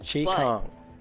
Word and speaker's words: Chi Kung: Okay, so I picked Chai or Chi Kung. I hope I didn't butcher it. – Chi Kung. – That's right.